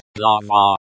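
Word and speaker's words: glava (глава): Pronounced glava in Russian.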